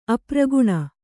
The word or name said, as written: ಅಪ್ರಗುಣ